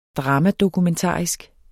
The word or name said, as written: dramadokumentarisk